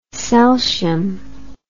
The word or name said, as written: celtium